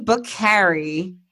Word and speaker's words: Bokhari: So my guest today is Dean Bokhari.